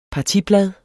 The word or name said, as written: partiblad